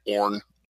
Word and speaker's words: Oren: But you could basically just pronounce his name Oren. – Okay. – One syllable.